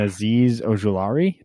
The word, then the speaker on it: Azeez Ojulari: Out of USC, and Azeez Ojulari.